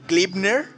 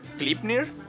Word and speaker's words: Gleipnir: Estoy hablando de Klipner. Klipner, no sé cómo se menciona Klipner. Se escribe G-L-E-I-P-N-I-R. N tengo un humor estúpido, amigos. Pero Gleipnir, creo que se menciona así, es un anime de 12 capítulos que fue estrenado el 5 de abril del 2020.